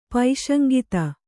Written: ಪೈಶಂಗಿತ

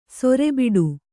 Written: ಸೆರೆಬಿಡು

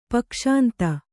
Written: ಪಕ್ಷಾಂತ

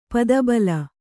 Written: ಪದಬಲ

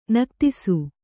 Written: ನಿಗದಿಸು

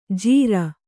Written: ಜಿರಾ

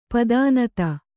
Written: ಪದಿನೈದು

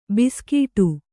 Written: ಭುಸುಗುಡು

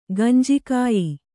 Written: ಗಂಜಿಕಾಯಿ

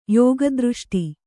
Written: ಯೋಗದೃಷ್ಟಿ

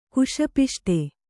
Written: ಕುಶಪಿಷ್ಟೆ

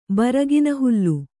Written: ಬರಗಿನಹುಲ್ಲು

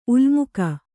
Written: ಉಲ್ಮುಕ

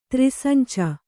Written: ತ್ರಿಸಂಚ

